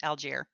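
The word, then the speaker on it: Allgeier: Is that Algier? Okay. – It's how they pronounce it, Algier.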